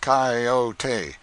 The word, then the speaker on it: coyote: If I remember rightly, this latter was the regular coyote, pronounced coyote, of the farther deserts.